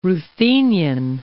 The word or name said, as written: Ruthenian